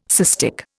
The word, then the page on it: cystic: Indian